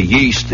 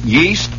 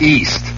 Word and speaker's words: yeast: Do they pronounce it yeast or do they pronounce it east? Yeast. They pronounce the Y.